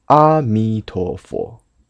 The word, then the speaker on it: 阿弥陀佛: 佛号的读音，再说一下：阿弥陀佛，不是阿。梵文的原音是 Amitabha，就是开口音啊。